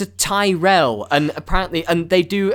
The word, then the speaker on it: Tyrell: I don't know if you picked up on it based on what I've been saying, but the correct way to pronounce their surname is Tyrell. – Okay. – Not the crisps, like Tyrrells. Sorry, for Americans. Potato chips, like Tyrrells.